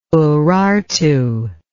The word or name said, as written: urartu